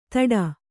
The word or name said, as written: ತಡ